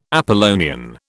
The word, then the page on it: Apollonian: British English